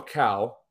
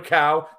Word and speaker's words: Kao: So without any further delay, Mr. Daniel Cow, okay, right? Did I pronounce that right? – Cow, yeah. – Daniel Cow, the CEO of TruckSmarter, man.